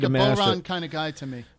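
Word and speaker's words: bodhrán: You have what's called a boron, when the D is silent, I think.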